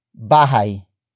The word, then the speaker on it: bahay: And that noun is bahay. Bahay. Bahay. Bahay is spelled as B-A-H-A-Y.